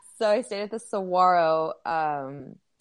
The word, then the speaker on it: Saguaro: The G is not there. It's Saguaro.